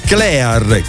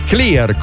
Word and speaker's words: Clear: Il mio amico Max, lo scrittore, invece, ricorda da Clear, che in realtà si dovrebbe pronunziare Clear, così come in realtà avremmo dovuto pronunciare Colgate, il dentifricio Colgate.